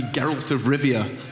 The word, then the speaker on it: Geralt of Rivia: Henry Cavill is going to be Geralt of Rivia, the White Wolf.